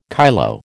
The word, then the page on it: kyloe: American English